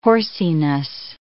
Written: horsiness

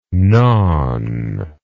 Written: gnawn

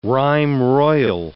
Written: rhyme royal

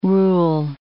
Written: rule

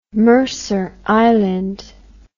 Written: Mercer Island

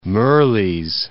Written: Mirrlees

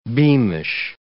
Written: beamish

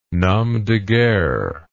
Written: nom de guerre

